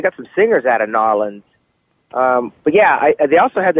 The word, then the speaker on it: New Orleans: In Nolens, that's how you say it. Nolens, right? – Nolens, that's all one, buddy.